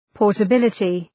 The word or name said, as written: portability